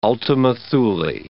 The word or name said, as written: ultima Thule